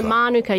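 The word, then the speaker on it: Mānuka: No, Manuka. You want the emphasis on the first syllable, so they're kind of right.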